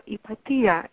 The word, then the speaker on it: Hypatia: Her name is Hypatia, if you pronounce it the English way, or Hypatia, if you pronounce it the Greek way.